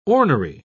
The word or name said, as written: ornery